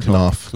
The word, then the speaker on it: Knopf: Am I pronouncing that right? – Knopf Publishing? – Knopf. – Knopf. – Yeah.